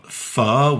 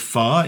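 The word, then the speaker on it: phở: Pho, which is Vietnamese noodle soup, and it looks like it's spelled P-H-O, pho. But those of you that want to pronounce it correctly, it's actually pho. It kind of goes up a little bit. Pho.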